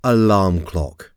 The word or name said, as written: alarm clock